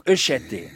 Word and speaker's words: Écheté: Vous avez le E muet, dans Écheté, le premier E est muet, le deuxième est proche de l'accent grave. Et le dernier, c'est l'accent aigu qui lui rend le mieux justice.